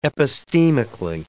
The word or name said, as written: epistemically